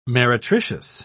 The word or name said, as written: meretricious